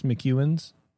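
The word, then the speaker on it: McEwan's: M-C-E-W-A-N, apostrophe S, McEwen's.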